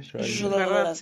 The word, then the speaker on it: Schrödinger: Y bueno, básicamente la premisa de esta película está basada en esa del, gato de Schrödinger. ¿Así se pronuncia? – Schrödinger. sí, sí.